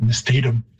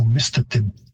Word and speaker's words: mistatim: And you've got a stress shift because English tends to like, many words are stressed on the second last syllable, so you get mistatum. And the Cree stress or pitch system would have a higher pitch on the first syllable, so mistatim. It means horse. It's a joining of two elements, mist for big and atim for dog, mistatim.